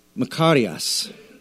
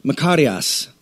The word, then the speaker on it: Makarios: The Greek word for blessed is a word called Makarios. And you have to roll your R.